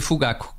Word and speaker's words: Fugaku: Convém garantir que se pronuncia Fugaku, com o acento tónico na penúltima sílaba e não na última sílaba.